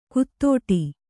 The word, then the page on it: ಕುತ್ತೋಟಿ: kuttōṭi